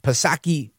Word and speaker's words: Psaki: Psaki with the silent P.